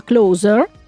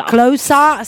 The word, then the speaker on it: Closer: Quindi questo album che si chiama Closer, l'ho detto bene? – Benissimo, bravissimo. – Closer, se lo dici come gli inglesi, sì.